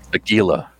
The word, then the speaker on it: Aguila: Aguila? Is that how you say it? – Aguila.